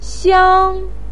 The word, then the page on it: xiang1: pinyin